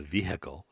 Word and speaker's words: vehicle: So if you hear somebody say the vehicle, you could say, yes, the vehicle was, right? So you drop the H because it is silent.